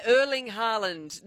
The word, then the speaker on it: Erling Haaland: Yeah, Erling Haaland, Norwegian striker.